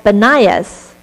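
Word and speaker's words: Banias: In fact, the modern name of this place in Israel is called Benias, which is an Arabic pronunciation of Panias.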